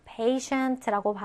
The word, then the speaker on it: patient: 자, 이때 t는요. 발음을 슈, 발음으로 한다라 그랬죠? patient라고 발음을 합니다.